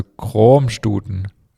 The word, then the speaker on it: Kroamstuten: Dann gibt es Kroamstuten, wird mit einem langen, offenen O ausgesprochen. Das A ist stumm, ähnlich wie das O in kommen.